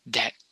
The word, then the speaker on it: debt: to pay the death. Death. I don't know why I pronounce that right. DEPT. Death. Death. Death. Debt. Yeah, death is more like a deep thing. Debt. To pay the debt.